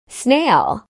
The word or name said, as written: snail